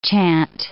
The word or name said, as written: chant